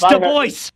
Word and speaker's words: Du Bois: It's pronounced Du Bois.